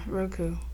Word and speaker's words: Roku: I literally had to download Roku. Is that how you say it? – Yeah, Roku.